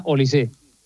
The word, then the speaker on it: Olise: Lleva acento, pero se pronuncia Olise. – Olise. O sea, es apellido francés. – Sí. – Vale, es que no sabíamos si lo de francés era por el padre o la madre. O sea, que es pero es raro que si no lleva acento que se pronuncie Olise. – Claro, si no lleva acento sería Olis. – Es Olis.